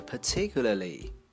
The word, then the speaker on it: particularly: 来下一个单词 particularly，独特的、显著的。再次提醒不要读成 particularly。下一个 stick，手杖、刺、掐住。